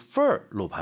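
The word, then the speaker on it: fare: fair. 이게 발음이 FARE가 영국 쪽은 fair, 그리고 미국 쪽은 fur로 발음을 하더라고요.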